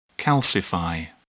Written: calcify

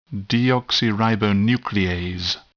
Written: deoxyribonuclease

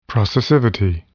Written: processivity

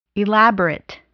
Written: elaborate

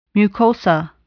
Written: mucosa

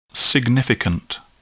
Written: significant

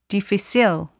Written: difficile

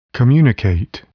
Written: communicate

